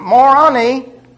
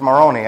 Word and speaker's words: Moroni: Hey, I said it right. Moroni. It's Moroni, actually.